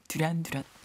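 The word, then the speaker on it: Duran Duran: 여자아이들의 인기를 구가했던 듀란 듀란 듀란 듀란 제대로 원어 발음해 주세요.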